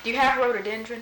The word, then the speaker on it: rhododendron: Rhododendron.